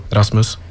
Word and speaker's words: Rasmus: Yeah, my name is Rasmus. It sounds quite the same in Swedish, so Rasmus.